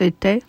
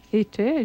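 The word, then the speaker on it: Étaix: It's spelled E-T-A-I-X, right? – E-T-A-I-X with an accent. – It's pronounced Ete. – Ete, je crois.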